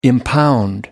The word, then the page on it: impound: American English